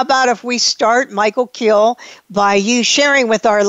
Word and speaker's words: Kiel: Michael, is it pronounced Kiel or Kyle? – It's pronounced Kiel. – Kiel.